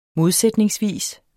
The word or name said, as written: modsætningsvis